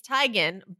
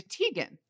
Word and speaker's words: Teigen: You can find, like, if you Google Chrissy Teigen pronunciation, there are lots and lots of interviews where she was like, actually, it's Teigen, but it hasn't stuck.